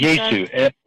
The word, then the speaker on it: Yaesu: And then you had mentioned, how do you pronounce it? Yaesu or Icom? – Yaesu. Y-A-E-S-U. – Y-A-E-S-U.